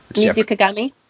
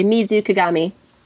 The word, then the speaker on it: Mizukagami: Mizukagami? – Ah, damn, you proved me wrong. Yep, that's what it was. – Wait, say it again. – Mizukagami.